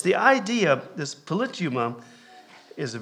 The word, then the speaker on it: politeuma: And if you look it up, it's transliterated, like in Strong's dictionary and some of the English dictionaries from the Greek, it's transliterated as polituma. And you spell it P-O-L-I-T-E-U-M-A, polituma, is how it's transliterated into the English dictionaries.